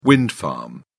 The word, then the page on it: wind farm: British English